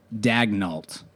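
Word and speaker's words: Daigneault: And what I have been told the pronunciation is Dagnault. – There's no way that's right. Like, maybe people are saying it that way, but when I look at that, I think, like, you know, Louisiana Cajun, I think Dagnault or Dagnault or something like that.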